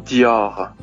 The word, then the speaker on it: Dior: 好的，第一个呢，Dior，Christian Dior；第二个，香奈儿，Chanel；第三个，Louis Vuitton，Louis Vuitton；第四个。